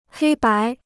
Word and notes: 黑白: hēi bái

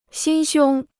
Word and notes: 心胸: xīn xiōng

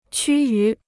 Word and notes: 趋于: qū yú

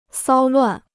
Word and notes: 骚乱: sāo luàn

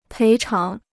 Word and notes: 赔偿: péi cháng

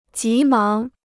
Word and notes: 急忙: jí máng

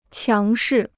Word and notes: 强势: qiáng shì

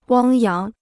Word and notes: 汪洋: wāng yáng

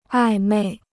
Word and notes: 暧昧: ài mèi

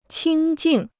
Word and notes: 清静: qīng jìng

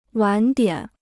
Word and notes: 晚点: wǎn diǎn

